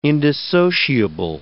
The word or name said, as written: indissociable